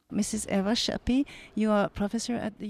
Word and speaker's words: Sapi: Son nom s'écrit Sapi, mais se prononce Chapi car elle est d'origine hongroise.